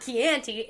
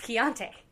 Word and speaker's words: Chianti: So I'm like, no, Matt, it's not Chianti, it's Chianti.